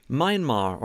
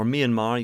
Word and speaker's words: Myanmar: Myanmar, or Myanmar, you can pronounce it either way you want, formerly Burma.